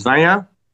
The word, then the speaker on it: Vanya: Well, like you say, my name is Vanya. Good job on the pronunciation. It's not an easy thing.